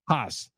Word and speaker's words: Haase: Haas, H-A-A-S-E, which is pronounced like Hazy and a million different names in the state.